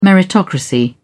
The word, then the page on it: meritocracy: British English